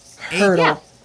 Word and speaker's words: Heardle: Am I saying this right? Hurdle? – Hurdle. Yeah. – It's pronounced Hurdle, H-E-A-R-D-L-E.